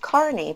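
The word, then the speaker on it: Kearny: And weirdly, it's pronounced Kearney because nothing in America makes sense.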